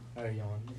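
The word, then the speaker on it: Ercan: Erjan, even though it looks like Erkan.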